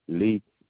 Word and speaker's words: Lee: Tommy, T-O-M-M-Y, Lee, L-E-E, Golightly, G-O-L-I-G-H-T-L-Y.